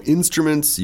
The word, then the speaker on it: instruments: Be careful with the pronunciation. Instruments. – Right, not instruments. – Instruments. – It's very, very, it's a big word, but, you know, just take away the U there in the middle. Instruments.